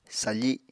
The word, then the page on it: safi: Apatani